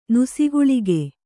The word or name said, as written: ನುಸಿಗುಳಿಗೆ